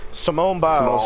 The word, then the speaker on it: Simone Biles: Simon Biles. Oh, I'm sorry. I did not mean to mispronounce a name like that. I said Simon too. Simone Biles. – Simone.